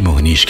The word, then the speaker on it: Mohnish: मोहनीश